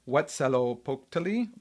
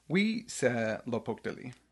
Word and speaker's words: Huitzilopochtli: It said either Huitzilopochtli or Huitzilopochtli.